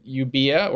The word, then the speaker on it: Euboea: And Eretria is on the island of Euboea, or Evia, as it would be pronounced, today, which is north.